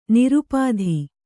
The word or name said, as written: ನಿರುಪಾಧಿ